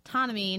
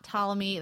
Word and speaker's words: Ptolemy: It's Ptolemy, not Ptolemy. That's my bad.